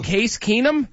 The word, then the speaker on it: Case Keenum: Case Keenum? – Yeah.